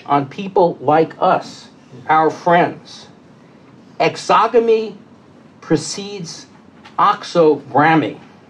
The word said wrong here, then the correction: exogramy: Excuse me, because now I mispronounced my own made-up word. Exogamy precedes exogramy. Exogramy.